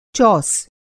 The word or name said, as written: चौस